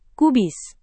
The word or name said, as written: kubis